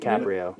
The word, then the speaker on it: DiCaprio: DiCaprio. – Yeah, DiCaprio.